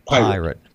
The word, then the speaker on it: Pyrate: You spell it P-Y-R-A-T-E. – Pirate. Pirate Radio. – Pirate with a Y.